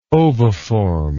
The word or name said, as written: oviform